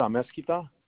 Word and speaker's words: Amezquita: David Amezquita.